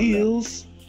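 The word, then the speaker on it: Elwes: I say Ewes. I don't know. – Ewes. Yeah.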